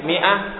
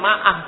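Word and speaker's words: mi'ah (مائة): Mi'ah, nah, jadi mi'ah itu ada alif zaidahnya. Walaupun dibacanya mi'ah, ada sebagai ifrah bahasanya ma'ah kiranya. Bukan, ini ada alif tambahan yang enggak dibaca. Bukan bahasanya ma'ah, enggak bacanya mi'ah, tapi mi'ah tetap. Jadi adanya alif ini enggak dibaca, tetap dibacanya gini.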